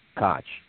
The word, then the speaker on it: Koch: Greg, I think he pronounces it Koch. I don't think it's Coke like the guy at Sam Adams Gym.